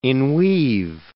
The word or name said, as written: inweave